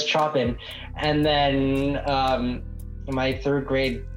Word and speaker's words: Chopin: Yep, Chopin. – Oh, is that how you say it? – Yeah.